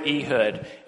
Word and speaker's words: Ehud: Okay, so it brings me to the story of Ehud. Am I saying that right? Yeah. the story of Ehud.